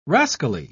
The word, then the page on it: rascally: British English